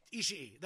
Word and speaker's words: Ishii: I don't know how to pronounce it. Tomohiro Ishii. – Ishii. That's right.